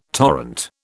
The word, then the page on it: torrent: British English